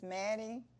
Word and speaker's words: Mattie: My name is Mattie.